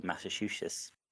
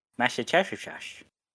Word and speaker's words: Massachusetts: Lovecraft verse in Massachusetts. That was not how you pronounce that word at all. Massachusetts.